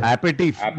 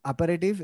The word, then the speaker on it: aperitif: Baba, the pun is aperitif is pronounced as a pair of teeth.